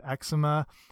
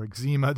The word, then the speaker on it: eczema: It can also reduce symptoms of eczema um, or eczema, depending how you like to pronounce it.